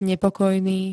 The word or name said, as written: nepokojný